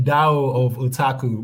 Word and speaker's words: Tao of Otaku: It was called, I cannot say the word that well, it's Tower of a Tackle, something like that. Can you say it properly? The original name, Tao of... – Yeah, the Tao of Otaku.